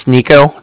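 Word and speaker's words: Niko: My name's Nico. That's N-I-K-O. I was calling with the Bank of Cardiff, and Cardiff is spelled C-A-R-D-I-F-F, as in Frank Frank.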